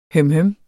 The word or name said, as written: hømhøm